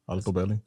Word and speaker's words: Altobelli: So there was John Altobelli. Yeah, Altobelli.